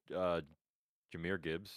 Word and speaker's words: Jahmyr Gibbs: No, we got, so the odds right now sit CJ Stroud 1, Puka Nuka 2nd, 3rd is Jahmyr Gibbs.